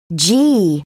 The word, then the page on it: gee: British English